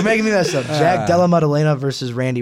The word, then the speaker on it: Della Maddalena: Don't understand how, but it's our boy Randy Brown taking on Jack Dellen Maddalena? I don't know how you pronounce that. – Sounds right. – Della Maddalena.